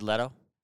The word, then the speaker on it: Leto: Jared Leto. Can you say that? – Because it rhymes with ghetto.